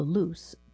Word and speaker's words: Luce: Online, the Luce, pronounced Luce, or light in Italian, drew plenty of comparisons to the Nissan Leaf, an EV that costs about $500,000 less.